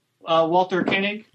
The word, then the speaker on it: Walter Koenig: Walter Koenig.